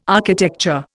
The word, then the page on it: architecture: Indian